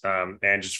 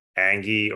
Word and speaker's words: Ange: So the stuff that I've learned once I figured out of the 9 pronunciations that I thought of to pronounce Ange's first name, once I feel good about it being Ange and not Angie or Anga or Angie or the other iterations that I was thinking of, yeah, I feel excited about it.